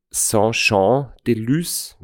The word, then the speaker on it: Saint-Jean-de-Luz: Vielleicht ein kurzer Abriss, der Start und das Ziel sind in Saint-Jean-de-Luz, wenn ich es jetzt hoffentlich richtig ausgesprochen habe, ja, in Frankreich. – 100%ig richtig. Okay. Perfektes Französisch.